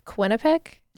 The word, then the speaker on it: Quinnipiac: I took, hopefully I pronounced this right, Quinnipiac. – Yep.